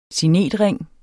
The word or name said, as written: signetring